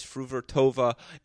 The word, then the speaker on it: Fruhvirtova: I'm talking about Linda Fruvertova, a name we're all going to have to learn to pronounce as Fruvertova,